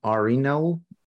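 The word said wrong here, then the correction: Aarnio: am I right? – Arnier, yeah.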